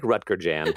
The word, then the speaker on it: Rutger Jan: The closest I could get is Rutger Jan. I was born in Amsterdam. It's Dutch. But